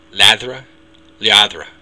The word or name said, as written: leadradh